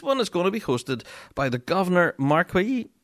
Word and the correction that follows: Macquarie: Memorial Pipe Band. Let's probably pronounce that wrong again. – Stuff. – Yeah, the Governor Macquarie Memorial Pipe Band from Sydney, Australia, are hosting an online contest, which is going to be something really quite unique.